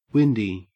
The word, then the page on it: windy: Australian English